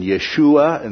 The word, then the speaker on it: Yeshua: One of the things to point out is Jesus, as we pronounce it, it would have been Yeshua in the original language.